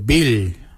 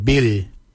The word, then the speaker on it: bily: Korbu is spelled G-O-O-L-Y-B-O-L. Korbu. Korbu is your belly. Your belly button is your bil. Your bill. Your bill is spelled B-I-L-Y. Bill. The nipple, we say bibi. Bibi is spelled B-I-B-I.